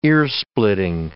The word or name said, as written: earsplitting